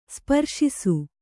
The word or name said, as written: ಸ್ಪರ್ಶಿಸು